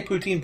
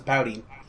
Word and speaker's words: poutine: It can't say poutine properly. It says pouting.